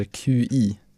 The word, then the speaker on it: Qi: I nuläget, alltså i dagens teknikvärld, så är Qi, eller QI uttals Qi, en av marknadens största standard för trådlös laddning.